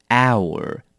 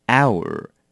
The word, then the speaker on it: hour: 这个字也要小心的是，它的 h 也不发音，所以是念成 hour，不是 hour， OK？ hour。